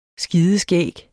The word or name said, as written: skideskæg